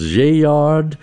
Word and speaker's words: Gaillard: Dinah Washington, 1949, and Slim Gaillard. I'm told it also could be pronounced Gaillard. And if it was pronounced the French way, they'd pronounce it a whole different way, but he was American, so.